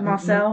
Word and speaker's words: Moselle: In June 2021, Alex Murdaugh shot and killed his wife Maggie and their son Paul on the grounds of how do you pronounce that? It's uh, Moselle. M- Moselle. Moselle.